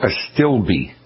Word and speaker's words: Astilbe: They're A-S-T-I-L-B-E. Is it Astilbs? Or? – It's called Astilbe.